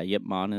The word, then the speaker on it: Ip Man: I think, yeah, Ip Man is the actual pronunciation.